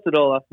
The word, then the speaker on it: Stodola: I hope I pronounced that correctly, Phil. – Pretty close, yeah. Phil Stodola, no big deal. People get it wrong.